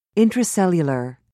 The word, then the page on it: intracellular: British English